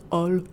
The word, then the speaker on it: Holl: So my last name in French is Ol. – Yeah. – Because you don't pronounce H's.